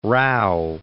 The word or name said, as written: Rao